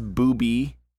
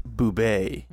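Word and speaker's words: Boobe: like, no, it's the French pronunciation. It's pronounced boubé.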